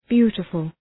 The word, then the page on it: beautiful: ‘bju:təfəl